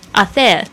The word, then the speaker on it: hacer: OK， Como 就是呢如何 how 的意思， hacer 这个字呢它这个 H 不发音， H-A-C-E-R 是这个做的意思，就是 do Paella P-A-E-L-L-A，特别注意一下这边西班牙语的这个 double L 这个两个 L 它是不发了的声音，它发 yeah 的声音。